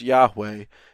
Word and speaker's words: Yahweh: We say Yahweh as maybe a close pronunciation of what it would be.